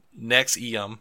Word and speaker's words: NXIVM: pronounced NXIVM.